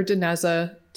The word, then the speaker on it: Dinezza: My channel name is Dr. Dineza, so it's spelled D-I-N-E-Z-Z-A, Gut Microbiome Queen.